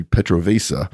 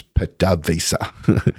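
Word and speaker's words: PDVSA: So basically, Maximilius, love the name, Maximilius Damus tells me, I wonder why you pronounce the Venezuelan country Petrovesa instead of the right way, which is Petavesa.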